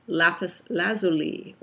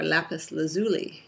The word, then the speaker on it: lapis lazuli: I'm making a video today about the gemstone lapis lazuli or lapis lazuli. You can pronounce it either way, both are correct.